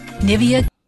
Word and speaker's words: Nivea: Nivea. – There you go. – Although you can hear that, that's the American version. If you can find the French version.